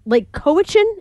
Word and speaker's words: Lake Cowichan: The kid was 7, and it was right outside their family home in Lake Cowichan.